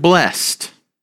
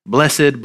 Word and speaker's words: blessed: Now, remember last week we talked about that word blessed. Blessed, blessed, same words, just a different emphasis on a different syllable, right?